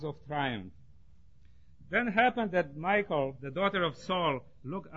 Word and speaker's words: Michal: David's wife's name was Michal. Is that right? Saul's daughter? Michal? Thank you. It's easier for me to pronounce Michal than Michal.